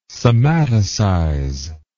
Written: somaticize

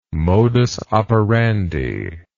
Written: modus operandi

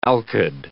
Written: alkyd

